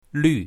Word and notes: lv4: mandarin

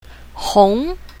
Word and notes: hong2: mandarin

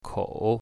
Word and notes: kou3: mandarin

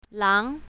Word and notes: lang2: mandarin

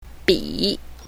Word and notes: bi3: mandarin

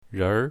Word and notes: renr2: mandarin

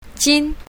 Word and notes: jin1: mandarin

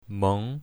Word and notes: meng2: mandarin

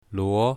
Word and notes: luo2: mandarin

